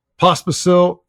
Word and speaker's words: Pospisil: It feels like Ross, Pospisil, and Erinee.